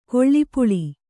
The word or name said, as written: ಗಾಲಿಪಲ್